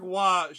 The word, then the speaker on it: Roy: Patrick Waugh should have had an ER, even though it's spelled Roy because it's called Waugh.